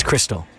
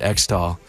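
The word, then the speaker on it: Xtal: However, I've been told by a very helpful listener, it's Crystal. Like Xmas for Christmas. Never thought of it like that. So thank you very much. I will probably keep pronouncing it X-Doll, but now I know how it's supposed to be pronounced.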